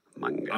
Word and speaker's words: manga: Manga? – It's manga. Don't say manga. – Yeah, if you say manga, I think people get upset. It's manga. – Manga. – Yeah. – Manga.